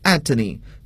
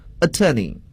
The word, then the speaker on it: attorney: 好了，大家注意，这个词呢在美国英语中呢要读作 attorney，但是在英国英语中呢则读作 attorney。